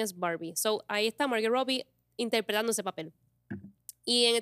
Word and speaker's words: Margot Robbie: Barbie es una persona, la Barbie que es Margot Robbie, la primera, ¿Lo dije bien su nombre? – Sí. – Margot Robbie.